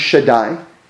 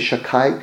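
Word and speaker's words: Shaddai (שדי): Sometimes you just see a shin, but if that shin is there, it's just abbreviating this name of God, Shin Dalad Yud, pronounced Shaddai. Some people say Shakai because they don't want to pronounce the name of God.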